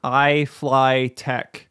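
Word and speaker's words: iFlytek: And this one's called iFly Tech.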